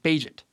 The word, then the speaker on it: Bagent: At first, I called him Bajent. I didn't know how to say his name actually. Like he was so out of the box for me. I was like, how do I even say his name? It's B Agent, Bajent.